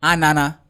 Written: ANANA

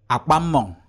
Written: akpammong

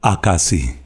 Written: Akasi

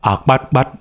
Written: Akpatkpat

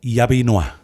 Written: Yapinua

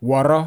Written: Wawraw

